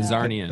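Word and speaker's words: Czarnian: How do you pronounce it? – I pronounce it like the Russian czars. – Zarnian. Yeah, Zarnian. – That's probably accurate.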